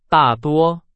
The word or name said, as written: 大多